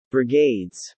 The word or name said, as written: brigades